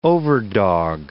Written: overdog

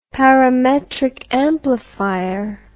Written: parametric amplifier

